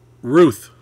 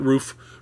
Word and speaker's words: Ruth: Ruth, say it right, not Roof, Ruth.